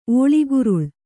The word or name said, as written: ಒಳುಗೊರಳು